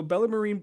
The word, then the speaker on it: Bellarmine: Bellarmine.